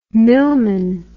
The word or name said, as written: millman